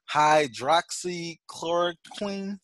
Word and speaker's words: hydroxychloroquine: So he allegedly shipped undercover agent a package including hydroxychloroquine.